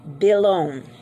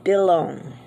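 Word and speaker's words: Bilon (וילון): L-O-O-A-C-H. Luach. Blind, like for the windows. Bilon. V-I-L-O-N. Your V is pronounced as a B. So it's Bilon. Next, blocks, like toy blocks. Kubiyat. K-O-O-B-I-Y-O-T.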